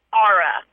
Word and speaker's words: Aura: Aura. Like Aura. The ghetto R. Aura.